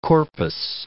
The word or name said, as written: corpus